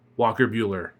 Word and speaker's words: Walker Buehler: They could start Walker Buehler.